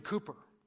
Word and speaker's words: Cowper: And Newton befriended Cooper. By the way, if you look in your hymnal, it's spelled C-O-W-P-E-R, Cowper, but it's actually pronounced Cooper.